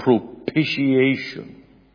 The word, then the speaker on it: propitiation: You don't pronounce the T's in this word. It's a propitiation through faith in his blood.